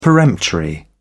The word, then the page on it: peremptory: British English